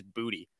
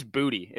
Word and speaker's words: Boutte: If you want to throw Boutte in in there still as well, I think actually the official pronunciations came out, and it actually is Booty. Apparently, it is Booty. – Yeah, it is Booty.